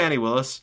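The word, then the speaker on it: Fani Willis: Yeah, Fannie Willis.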